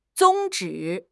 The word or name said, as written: 宗旨